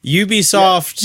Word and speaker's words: Ubisoft: See, on our last big, huge nugget here is Ubisoft. I just said it wrong again already. Ubisoft. – Yeah, you can't pit Splinter Cell anything until you get the name right.